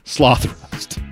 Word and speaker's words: Slothrust: And so thankfully, that's not how it's pronounced. It's Slothrust.